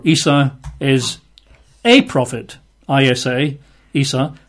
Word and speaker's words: Isa: They call him Isa. That's I-S-A, pronounced Isa.